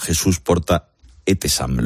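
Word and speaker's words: Jesús Porta-Etessam: Con la ayuda del doctor Jesús Porta Etesam. Lo he dicho bien, don Jesús, buenas tardes. – Lo ha dicho perfectamente.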